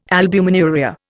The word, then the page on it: albuminuria: Indian